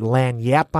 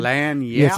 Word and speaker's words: lagniappe: and I put Lan Yap on the side. – Lan Yap. – It's actually spelled LAGNIAPPE, but it's pronounced Lan Yap, and it's like a slang phrase and Creole slang phrase for something, a little something extra.